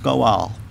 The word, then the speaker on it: gaal: The word avenger in the Hebrew is the word goal. G-A-A-L, pronounced goal.